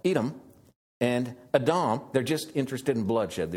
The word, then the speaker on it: Edom: Mount Seir is Edom, and that's how you pronounce it. Adam. And blood is Adam. Well, it's different letters in Hebrew, but they're pronounced the same.